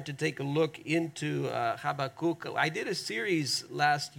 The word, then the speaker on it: Habakkuk: We often say Habakkuk, but it's Habakkuk, and it means to struggle and to embrace at the same time.